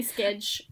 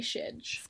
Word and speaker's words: schedule: So some say skedge, some say shedge.